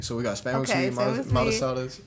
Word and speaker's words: Spam musubi: The spam musubi. Mm-hmm. Like the, I don't even know how to pronounce it right, but. – Spam musubi. Musubi. Yeah.